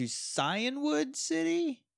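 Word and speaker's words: Cianwood: I don't know if it's a hard C or a soft C. I think it's a soft C. So we're going to Cyanwood City? – Is it an I? – It's an I. – Ugh. – C-I-A-N. – I've always pronounced it Cyanwood, but I know that's wrong.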